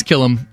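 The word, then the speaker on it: Killum: Last name is Killam. – Okay. – I think it's K-I-L-L-U-M. – Oh, gracious. That's how you pronounce it.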